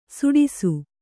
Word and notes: ಸುಡಿಸು: suḍisu

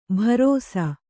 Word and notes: ಭರೋಸ: kannada

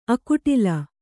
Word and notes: ಅಕುಟಿಲ: akuṭila

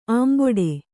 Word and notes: ಅಂಬಾಡೆ: ambāḍe

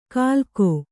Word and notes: ಕಾಲ್ಕೋ: kālkō

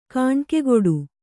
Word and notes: ಕಾಣಿಕೆಗೊಡು: kāṇikegoḍu

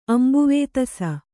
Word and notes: ಅಂಬುವೇತಸ: kannada

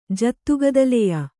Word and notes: ಜತ್ತುಗದಲೆಯ: kannada

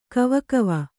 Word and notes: ಖವಖವ: khavakhava

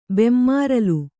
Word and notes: ಬೆಮ್ಮಾರಲು: bemmāralu

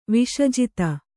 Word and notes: ವಿಷಜಿತ: viṣa jita

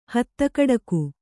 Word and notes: ಹತ್ತಕಡಕು: Kannada

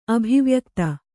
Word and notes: ಅಭಿವ್ಯಕ್ತಿ: kannada

